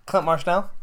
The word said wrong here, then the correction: Mansell: Okay, by the way, you guys, it's Mansell. I've been saying it wrong for months. What? Oh my gosh. It's Mansell? It's not Marshnell? – It's because I called him that.